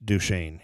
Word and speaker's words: Duchesne: Just Duchesne, you know, D-U-S-H-A-N-E. That is not how it's spelled, but that's how it's pronounced.